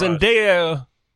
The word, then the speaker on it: Zendaya: Zendaya, did I say it right? – Yes.